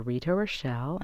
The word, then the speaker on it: Rita Rochelle: Simply mail your letters to Rita Rochelle, and I'll spell that for you. Rita is R-I-T-A. Rochelle is R-O-C-H-E-L-L-E.